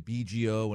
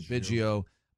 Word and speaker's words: Biggio: People saying BGO, and it's really Biggio.